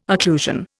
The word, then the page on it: occlusion: Indian